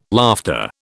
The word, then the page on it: laughter: British English